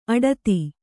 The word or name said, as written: ಆಡಿತ